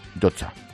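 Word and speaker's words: Llotja: Esa cumbre, el Consejo de Ministros en la Yotcha, ¿cómo se dice? ¿Yotcha? Yotcha. En la Yotcha.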